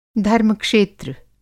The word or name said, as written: धर्म-क्षेत्र